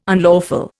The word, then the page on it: unlawful: Indian